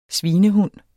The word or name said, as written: svinehund